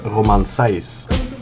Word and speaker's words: Romain Saïss: Amine, thank you for joining me and giving us a little bit of your insights on Besiktas' new signing, Romain Saïss.